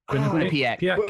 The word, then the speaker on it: Quinnipiac: Quinnipiac.